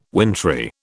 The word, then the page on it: wintry: British English